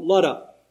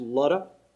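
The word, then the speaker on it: Lydda: He comes to a city called Luddah. And yes, that's the way it's pronounced. It's the Old Testament city of Lod or Lud. Depending on your Bible translation, it's either L-O-D or L-U-D. And even though there's a Y in it in the New Testament, it's still pronounced Luddah.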